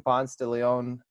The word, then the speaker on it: Ponce de Leon: He is the youth pastor at Second Ponce de Leon Baptist Church. I said that right, correct? – You did. You got it.